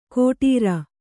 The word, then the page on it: ಕೋಟೀರ: kōṭīra